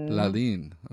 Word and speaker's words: Lalin: I've even started to pronounce my name differently now that I'm speaking the language. – How would we say it if we're being correct then? – LALIN. – Okay. Mm-hmm. My apologies. – LALIN. No. I have said LALIN for a very long time, but then if you know, I actually named myself that, and it means moon in Haitian Kreyòl.